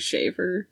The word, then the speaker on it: Schaeffer: It's S C H A E F F E R. I wouldn't know how else to pronounce it. So sounds like Shaver.